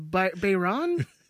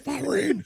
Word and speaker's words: Bahrain: It says you're in Bayron. – And there's no such thing as Bayron. Bahrain.